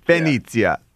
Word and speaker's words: Venezia: You know your family is actually from Venezia. Is that how you say it in the Italian? – Venezia.